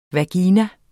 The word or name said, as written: vagina